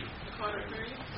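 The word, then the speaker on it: The Carter III: The Carter Third, or how do you pronounce that? The Carter Roman Three. – The Carter Three.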